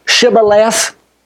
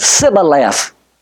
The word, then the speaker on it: Shibboleth: But these old boys knew that those people could not pronounce the H. And so whenever they attempted to say Shibboleth, they said Sibboleth.